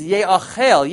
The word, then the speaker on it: Ye'achel: So the word is written as Yochal, but it's pronounced as Ye'achel.